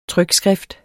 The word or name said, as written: trykskrift